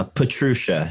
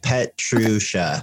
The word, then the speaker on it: Petrusha: Uh, Petrusha. So like Petrusha. Okay. Awesome. Pe- Petrusha. – Petrusha. – Yeah. Surprisingly Just phonetic. like it's spelled.